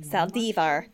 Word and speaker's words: Saldivar: Saldivar. That's Alexa's pronunciation.